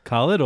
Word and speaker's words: Khaled: Khaled or Wahlberg? – Wahlberg. – They both have silent H's. – Well, I mean, silent H's in their name too.